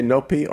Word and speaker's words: Nopi: Nopi, you can say Nopi or Nopi. It's up to you. – You just said the exact same word. – Nopi or Nopi? – Nopi. – No, that's different. Nopi.